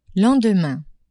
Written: lendemain

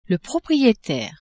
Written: proprietaire

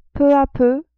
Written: peu a peu

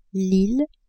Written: ile

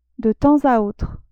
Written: de temps a autre

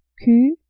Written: q

